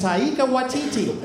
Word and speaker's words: Taika Waititi: His name is Taika Waititi.